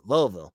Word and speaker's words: Louisville: They have Louisville, Louisville. Sorry, I say it like they do, Louisville, and it's a game on the road.